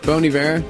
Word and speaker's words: Bon Iver: Bon Iver.